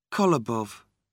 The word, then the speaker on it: Kolobov: Kolobov. – Can you spell it? – K-O-L-O-B-O-V.